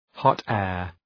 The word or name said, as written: hot air